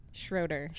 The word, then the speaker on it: Schroder: Schroder.